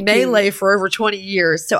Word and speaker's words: Malay: So anyway, the correct pronunciation, if you're wondering, is Malay, as in Malaysia.